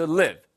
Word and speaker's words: live: 아까 나왔던 who wants to live. 간단하죠? 길게 발음하시면 누가 떠나고 싶겠어가 돼요. L-E-A-V-E. Who wants to leave? No, no. Who wants to live?